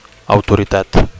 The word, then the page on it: autoritat: Catalan